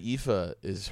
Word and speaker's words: Aoife: My friend Aoife, her name is pronounced so, it's like, first of all, there's like three vowels to start the name.